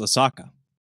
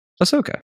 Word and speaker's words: Ahsoka: I thought it was Osaka. Ahsoka. That's how you say it.